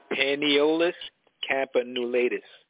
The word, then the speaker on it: Panaeolus campanulatus: How do you pronounce it? So I can read it again. – Panaeolus campanulatus.